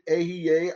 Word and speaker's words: Ehyeh: I pronounce it Ehyeh, I share Ehyeh. You'll hear some people pronounce it differently because of the vowel points.